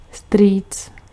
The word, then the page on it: uncle: American English; /ˈʌŋ.kəl/